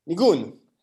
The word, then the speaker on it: nigun: So Negan is in Yiddish and it's meant, it's like... how do you say it? How do you say what Negan is? Nigun. It's like...